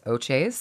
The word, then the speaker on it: Ohchays: Our married name was A-U-T-H-I-E-R, O-T-A. – Yeah, yeah. – But typically Americans in particular would just destroy the pronunciation. So we named the band O-H. C-H-A-Y-S, Oches, so that you could not screw it up.